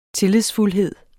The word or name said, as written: tillidsfuldhed